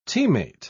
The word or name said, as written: teammate